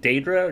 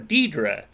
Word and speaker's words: Daedra: Well, it's funny because you hear NPCs in Oblivion pronounce Daedra two different ways also, and you'll hear NPCs comment on that, Daedra or Deidre. Like, I thought it was Daedra, but okay.